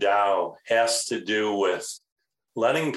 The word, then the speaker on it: Tao: Well, first of all, you pronounced Tao correctly.